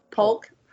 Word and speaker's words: Polk: Because people think our last name is Hulk all the time. Like, over the phone. Yes, what's your last name? Polk. – I always spell it. – I do, too. – P-O-L-K. – P-O-L-K, you nerd. – Like the president.